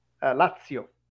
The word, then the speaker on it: Lazio: So, you will pronounce the place Lazio or Lazio? – Lazio.